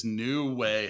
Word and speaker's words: Nuwae: Next on the list is New Way Health. And that's actually spelled N-U-W-A-E Health, but pronounced New Way.